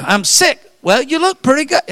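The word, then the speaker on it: Sikh: And I know we kid with this, but there's a religion, and it's spelled S-E-I-K. Some of us would mispronounce it and call it chic. Others would call it seek. It's pronounced S-I-C-K, sick.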